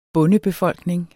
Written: bondebefolkning

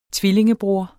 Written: tvillingebror

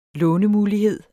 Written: lånemulighed

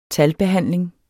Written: talbehandling

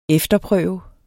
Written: efterprøve

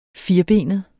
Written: firbenet

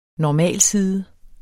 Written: normalside

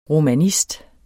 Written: romanist

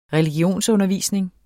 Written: religionsundervisning

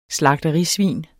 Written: slagterisvin

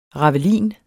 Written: ravelin

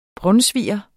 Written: brunsviger

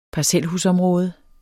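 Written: parcelhusområde

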